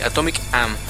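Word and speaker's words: Atomique Âme: De Atomic Ame. – Atomic Am. – Atomic Am. Disculpen la mala pronunciación.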